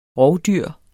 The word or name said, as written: ROVDYRENES